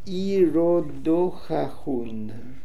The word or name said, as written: Irodohahoon